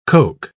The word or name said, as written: coke